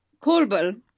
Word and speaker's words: Kolbl: Kolbl. – Kolbl. – I have to say it in my Slovenia accent.